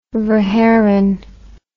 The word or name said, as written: verhaeren